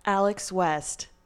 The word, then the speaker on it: Alex West: Alex West.